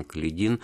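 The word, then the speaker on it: Каледин: Калидина, да, правильно так, делать ударение фамилии э, этого генерала и атамана Калидин, э, И то есть уже, так сказать, триумфальное шествие советской власти состоялось.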